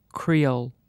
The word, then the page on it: creole: British English